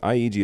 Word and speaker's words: Ihiji: For those of you listening and not watching, IEG is spelled IHIJI.